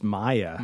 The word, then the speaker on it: Maia: And her name is... – It's Maya. – Maya, that's right. – Which, is that really a Japanese name? – It's spelled M-A-I-A.